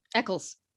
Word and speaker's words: Eccles: He's from, I'm not sure how to pronounce the name of this town, Eccles. – Eccles. – Eccles. Okay. He's from Eccles, England.